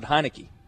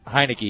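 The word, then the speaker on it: Heinicke: Tyler Heineke? Heineke? – Yeah, because he was so happy you pronounced it Heineke. – Heineke, yeah.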